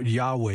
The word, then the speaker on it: Yahweh: Some of the old saints, especially with the King James Version of the Bible, they'll speak of this word as Jehovah. And in my humble opinion, the Yahweh is a closer pronunciation of this rather than Jehovah.